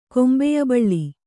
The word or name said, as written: ಕೊಂಬೆಯಬಳ್ಳಿ